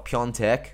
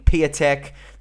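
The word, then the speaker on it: Piątek: And the other one that really, really bothered me was he pronounced Christoph Piontek, who I would like to think, you know, I'm a Serie A nerd. I get it. But, like, I don't think it's a far cry for you to be able to know how to pronounce this guy's name. He kept calling him Piatek. That's not how you fucking say it.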